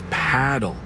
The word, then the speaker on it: Padel: Wait, is that Padel? – No, that's paddle. Rhymes with saddle.